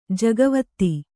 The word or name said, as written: ಜಗವತ್ತಿ